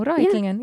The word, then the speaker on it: Reutlingen: Ja, Reutlingen, ich habe richtig gesagt, ich glaube. Ja, Reutlingen. – Genau, Reutlingen, ja.